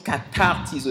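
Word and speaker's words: katartizo (καταρτίζω): Le mot perfectionnement, pour ceux qui n'étaient pas là, c'est un mot grec que l'on prononce katartizo.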